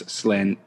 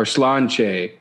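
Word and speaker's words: sláinte: You know, Chet Shanahan might have an understanding what this reference is, but it's a slanté is what it reads, but I think it's slanché or slanché is how you pronounce it.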